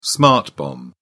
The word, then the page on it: smart bomb: British English